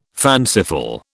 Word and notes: fanciful: British English

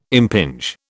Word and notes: impinge: British English

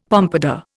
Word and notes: pompadour: Indian

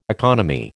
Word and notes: economy: American English

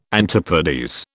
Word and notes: antipodes: British English

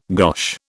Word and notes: gosh: British English

